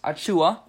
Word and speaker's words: Achiuwa: So it came out recently that Precious Achiuwa, did I pronounce his name right? – Yep.